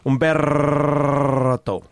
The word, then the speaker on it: Humberto: I want to learn how to say your name the way you say your name, Humberto. – Humberto. Oh, that was really good. – Oh, okay. Well, yeah. Okay. – I mean, when I say my name, I go like, Humberto.